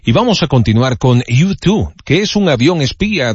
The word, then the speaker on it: U2: ya que se pronuncia igual a tú también en español, U2.